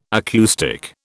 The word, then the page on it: acoustic: British English